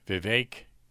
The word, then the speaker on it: Vivek: You think there's a possibility that Donald Trump and Vivek rhymes with cake, Ramaswamy 2024, man, and vice presidential candidate for Trump.